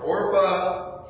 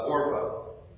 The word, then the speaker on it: Orpah: Now the names of the wives taken by Elimelech's sons were Orpah and Ruth.